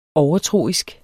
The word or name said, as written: overtroisk